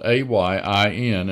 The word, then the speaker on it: ayin: The Hebrew decade of the 70s was an ayin decade, spelled A Y I N and pronounced ayin.